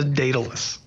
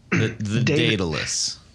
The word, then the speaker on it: Daedalus: Also, what we don't have in front of us is the, and now you're gonna have to pronounce this one too, Mr. Smarty Pants, with your pronunciation, overdrive based on the Blues Breaker circuit. – Yeah, that's the Daedalus. – Daedalus.